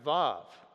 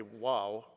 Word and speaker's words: Vav: the letters W-A-W, right? In English, it's pronounced Vav, okay? Stephen might say wow, right?